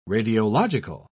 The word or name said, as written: radiological